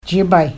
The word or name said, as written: jiibay